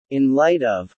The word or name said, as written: in light of